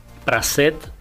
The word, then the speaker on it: Praseuth: Miguel Praset Cambonza Bernal.